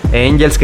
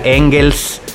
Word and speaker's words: Engels: Por eso le había puesto Angels, que realmente se suponía que era Engels. – Ok. – La pronunciación correcta, porque creo que así se llama una ciudad allá en Alemania y que su papá ya vivía.